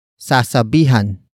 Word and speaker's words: sasabihan: Word for word, it's sasabihan ko ang super na pumara.